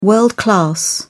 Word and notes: world-class: British English

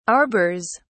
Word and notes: arbors: British English

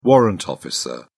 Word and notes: warrant officer: British English

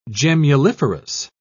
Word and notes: gemmuliferous: British English

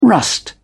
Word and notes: rust: British English

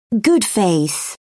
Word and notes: good faith: British English

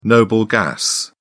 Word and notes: noble gas: British English

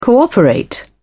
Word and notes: cooperate: British English

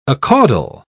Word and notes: acaudal: British English

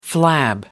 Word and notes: flab: American English

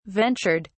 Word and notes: ventured: British English

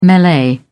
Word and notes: melee: British English

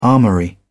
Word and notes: armoury: British English

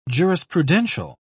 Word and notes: jurisprudential: British English

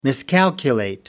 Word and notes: miscalculate: American English

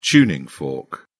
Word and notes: tuning fork: British English

